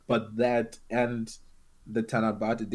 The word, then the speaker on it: Tanabata: Like particularly the Endless Eight and the Tanabate Day, how do you pronounce that? – Tanabata, I think.